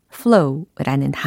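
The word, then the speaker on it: floe: 어디에 좌초되고 어디에서 오도가도 못하는 상황이냐면 in an ice flow라고 해서 어, I C E ice 그다음 flow F L O W 혹은 F L O E 예, 두개다 발음은 거의 비슷합니다.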